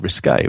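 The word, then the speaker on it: risqué: I said risque, which is exactly what I meant. You know what I meant? Tell me how I should be talking like. – Risque. – Risque. With an accent on the E.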